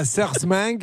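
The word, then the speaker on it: Sarreguemines: Dans la Moselle, à Sarsmeng, c'est ainsi que l'on prononce.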